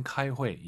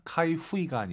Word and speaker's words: 开会: 明天 카이회 이게 C죠. 회의하다 카이후이가 아니라 UI 사이에 N 넣어서 카이회. 카이회 요 발음도 잘하셔야 됩니다. 카이후이 안 됩니다.